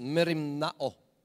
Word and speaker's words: merimnao: You don't have to master on that, but merimnao. That's how the Greeks would pronounce it, merimnao.